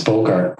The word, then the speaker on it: Bogart: It's Bogart, right? So as in Humphrey Bogart.